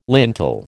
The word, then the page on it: lintel: American English